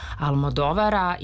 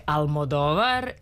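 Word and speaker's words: Almodóvar: To je novi film Pedra Almodovara i ja ću ponovo akcentovati tako, jer se kaže Una pelikula de Almodovar, iako smo mi navikli još od 90-ih, kada smo se ranih 90-ih upoznali sa njegovim radom i sa visokim potpeticama, se uvrežilo Almodovar.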